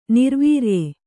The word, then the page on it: ನಿರ್ವಾರ್ಯ: nirvārya